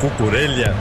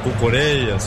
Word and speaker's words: Cucurella: Everton falava do Cucurella, o Cucurella, como queira, Cucurella, são várias as pronúncias aceitáveis, né? Mas o Cucurella e o Dumfries, o grande lateral holandês, serão os laterais de José Mourinho no Real Madrid.